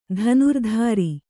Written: ಧನುರ್ಧಾರಿ

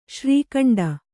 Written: ಶ್ರೀಖಂಡ

